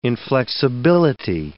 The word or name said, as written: inflexibility